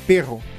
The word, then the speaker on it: perro: Só que na língua ele falava pero. – Pero, né? Aham. Sim, pero. Perro. Perro.